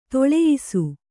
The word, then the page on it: ದೊಲ್ಲಯಿಸು: Kannada